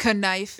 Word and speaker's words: knife: Why is the K a knife? – Knife. That's how you say it, Brie. – Knife. – It's knife.